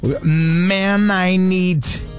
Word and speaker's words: Man I Need: Man I Need. É isso, né? Man I Need. – Perfeita a pronúncia.